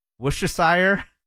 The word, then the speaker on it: Worcestershire: So you don't say the R, you don't say Worcestershire? – No. – Worcestershire.